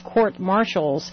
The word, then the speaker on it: courts-martial: And Tony Snow further elucidated it, saying judicial proceedings are the equivalent judicial proceedings such as military commissions, perhaps courts-martial. I know that's the correct way to say it. I always just want to add an S to the martial, too.